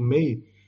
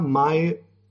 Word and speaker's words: Mae: No, é Ana Mi Barbosa, o MEI dela, é, apesar de, de, de, de ser dito MEI, se escreve, se escreve mãe sem o tio. – Ah, tá. – É Mae, Ana May Barbosa.